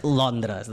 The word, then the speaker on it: Londres: Ben pronunciat, en català, és Londres, d'acord? Perquè comença això, comença a ser una nova tendència a traduir estopònims en el castellà directament, saps? – Vale, serà difícil, però ho concluirà.